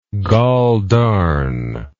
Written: goldarn

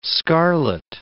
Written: scarlet

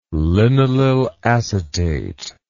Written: linalylacetate

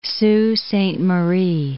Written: Sault Sainte Marie

